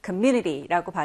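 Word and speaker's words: community: community라고 발음이 되어 중간에 여기 이렇게 U의 강세가 오게 됩니다.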